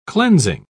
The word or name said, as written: cleansing